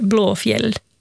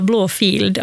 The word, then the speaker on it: Blåfield: Det som folk brukar tendera att uttala Blåfield, alltså blå, alltså en F-I-E-L-D, för att det är en gammal stavning av ordet fjäll. Så det namnet är Blåfjäll.